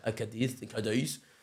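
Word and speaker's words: Cádiz: So yeah, I was sent out by Optus Sport and La Liga to cover Cadiz. That's how you're supposed to say it, Cadiz. Danny Perillo hates me for saying it like that. Cadiz.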